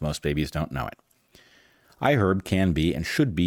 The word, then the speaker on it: iHerb: Our final sponsor for this episode is iHerb. Yep, you pronounce the H in iHerb.